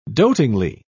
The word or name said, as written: dotingly